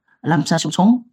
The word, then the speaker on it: Lapsang Souchong: So Zheng San Xiao Zhong, that is in Chinese pronunciation, but I think in English probably pronounces Lam San Xiu Chong.